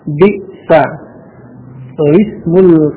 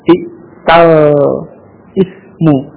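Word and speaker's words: bi'sa lismu (بئس الاسم): Menjadi bi'sa lismu. Ya. Bi'sa lismu. Bukan bi'tal ismu. Bukan. Ya. Jadi bukan bi'tal ismu, tetapi bi'sa lismu, ya, di surah Al-Hujurat ayat yang ke-11 nanti bisa dibaca, mungkin dipelajari di tajwid, ya, nanti akan dibaca semacam itu.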